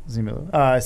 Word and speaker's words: Simu Liu: Yes, Simu Liu.